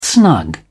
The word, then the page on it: snug: English; British English